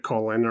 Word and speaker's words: Colin: It's spelled Colin, like C-O-L-I-N. – Oh, but he pronounces it Colin, or everybody does. That's weird. – That's the former Secretary of State, so that's not who I'm thinking of. Is there a, how do you spell the Colin Powell? – Yeah, that's how everyone says it, but it's spelled C-O-L-I-N. What? – Oh, it is C-O-L-I-N.